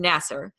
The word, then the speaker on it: Nassar: I would say Nassar. It's Nassar, who had ended up abusing about 150 girls on the gymnastics team.